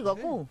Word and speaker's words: Goku: ¿No es Goku? ¿No se dice así? – Sí, Goku.